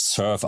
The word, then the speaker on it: Surfe: Äh, die Firma spricht sich Surf aus.